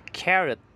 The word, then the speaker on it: carat: Yang kedua, carrot. Cara bacanya sama, tapi tulisannya sedikit berbeda. Tulisannya adalah carat. Okay. C-A-R-A-T. Jadi kalau tulisan yang pertama adalah karat, tulisan yang kedua adalah carat. Tapi cara baca keduanya sama, yaitu carrot.